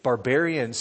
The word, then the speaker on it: barbarian: There's not Jew, Greek, Barbithian, how do you say that? Barbarian, Scythian, Jew.